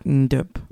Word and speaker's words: Ndox: It's more Ndop.